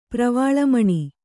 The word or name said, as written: ಪ್ರವಾಳಮಣಿ